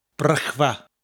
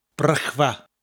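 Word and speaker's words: Brechfa: Spelled B-R-E-C-H, so the R and then the C-H gives us that distinct sound in the first syllable, Brech, followed by Va, spelled F-A with the F being a V sound, Va, Brychfa.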